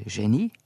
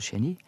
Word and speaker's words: geni: Det er akkurat akkurat samme ordet me har i begge tilfelle, men når uttalen i norsk er forskjellig, så kjem det rett og slett av at geni har me lånt direkte fra fransk, og da blir det geni, altså geni,